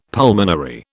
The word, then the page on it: pulmonary: British English